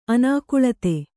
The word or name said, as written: ಅನಾಕುಳತೆ